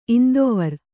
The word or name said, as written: ಇಂದುವಾರ